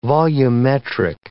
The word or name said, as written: volumetric